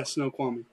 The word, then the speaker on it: Snoqualmie: And so is it Snoqualmie? Is that how you pronounce it? – Yeah, Snoqualmie.